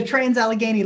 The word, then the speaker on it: Trans-Allegheny: Some of these locations include the Lizzie Borden House, the old Gilchrist County Jail, Brushy Mountain State Penitentiary, and I know I'm going to pronounce this one wrong, the Lunatic Asylum. – The Trans-Allegheny Lunatic Asylum.